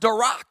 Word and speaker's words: darak: This word tread in the Hebrew is pronounced darak, and it means literally to take up your weapons and fight for.